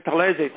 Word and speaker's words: Talese: But the point was, when I went to Italy, I discovered in 1970s, and then people would say Talese. Oh, right, so that's how you pronounce it. My first name, my grandfather, my father's father, was Gaetano, G-A-E-T-A-N-O, Gaetano.